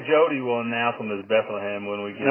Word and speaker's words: Bethlehem: So we have, and they pronounce it Bethlehem, not Bethlehem,